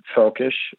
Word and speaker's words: Völkisch: It's sometimes translated literally into English as Völkisch, F-O-L-K-I-S-H. Which is more or less how you pronounce it in German, Völkisch,